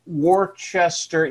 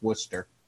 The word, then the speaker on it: Worcester: I'm like, I have to listen to it and I think to myself, Worcester. Yeah, that's probably how it would be pronounced by a normal person, but no, it's Worcester.